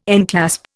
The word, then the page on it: enclasp: Indian